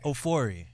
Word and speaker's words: Ofori: Ofori.